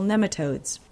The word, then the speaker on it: nematodes: The best control is to interrupt the life cycle with beneficial nematodes. Nematodes, it's pronounced like nemesis.